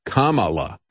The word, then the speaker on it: Kamala: I'm sorry, it's Kamala. That's the way you remember it, that little jingle. I have to play that in the back of my head to remember how to pronounce her name because I can never remember which syllable to put the emphasis on to pronounce her name correctly.